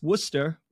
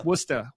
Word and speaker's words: Worcester: So there's a city in Massachusetts that is pronounced Worcester. some variation of that. But it's spelled Worcester. Worcester. It's spelled Worcester. – I didn't know that. – 99% of the country, you would read it phonetically Worcester.